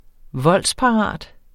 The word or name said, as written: voldsparat